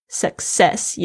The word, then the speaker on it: success: success 也是一样，这个 c 的发音跟呃 s 的声音，它两个 c 的发音都不一样，一个是 k，一个是 s。 success 虽然念的时候好像 k 的声音也是位置到，但不爆爆发出来，后面几着就发着 s 的声音。